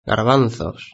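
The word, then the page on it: garbanzos: Spanish (Spain)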